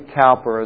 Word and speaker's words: Cowper: Verse 25, by the way, is William Cowper's salvation verse, or William Cowper, as we would pronounce it in English.